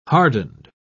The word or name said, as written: hardened